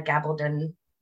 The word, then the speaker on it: Gabaldon: It is "Go Tell the Bees That I Am Gone" by Diana Gabaldon.